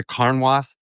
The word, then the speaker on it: Carnwath: Now there's the I went to Carnwath. Am I saying that right? – Yeah, yes. Carnwath Farm.